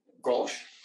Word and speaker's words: Grolsch: Grolsch.